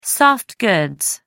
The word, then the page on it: soft goods: American English